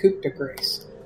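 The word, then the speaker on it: coup de grâce: Now, with a slight smile on her face, and only this little creature can see, I'm going to coup de grace the demon. – It's pronounced coup de grace.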